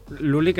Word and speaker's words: LVL1: Pero mm, estamos hablando de Luli, una persona Luli que se escribe LVL1, ¿vale? Porque habrá gente que lo habrá leído así.